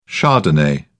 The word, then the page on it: chardonnay: British English